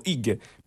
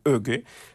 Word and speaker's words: hygge: se noi lo leggessimo da italiani senza pronuncia diremmo hygge, però è qualcosa che mi hanno spiegato, si chiama più hygge,